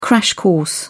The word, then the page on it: crash course: British English